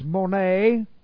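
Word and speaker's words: mone (μονή): Now, the Greek word, though, just to edify ourselves in this regard, the Greek word is mone. Looks like this. Mone, M-O-N, long E. Mone, and mone comes from the verb meno, which looks like this in the Greek, M-E-N, long O, meno.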